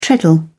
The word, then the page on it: treadle: British English